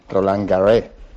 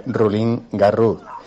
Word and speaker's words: Roland Garros: Soy profesor de francés y quería decirle a Paco que no se dice Roland Garros, se dice Rulín Garros.